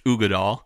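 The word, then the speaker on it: Uigeadail: They're Ugadal, which I'm not even going to spell it out because it won't even make sense to spell it, but it is pronounced Ugadal.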